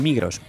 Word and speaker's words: Migros: La Migros, o mejor dicho, La Migro, porque la S no se pronuncia en realidad.